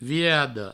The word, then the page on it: virada: virado